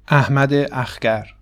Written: Ahmad Akhgar

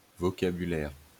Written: vocabulaire

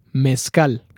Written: Mezcal

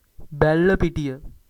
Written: Bellapitiya

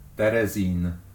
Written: Terezin

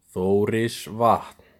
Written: Þórisvatn

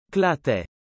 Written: Kelantan

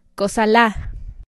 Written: Cosalá

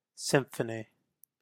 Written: Symphony

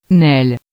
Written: Nesles

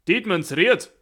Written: Dietmannsried

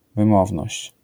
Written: wymowność

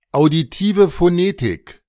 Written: Auditive Phonetik

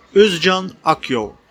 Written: Özcan Akyol

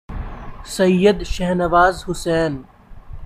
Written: Syed Shahnawaz Hussain

